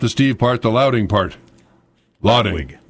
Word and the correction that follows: Ladwig: Ladwig. – Oh, wig. – No one's ever going to forget it. – W-H-I-G, like the party. – No, L-A-D-W-I-G. – Right, but wig is how it's pronounced.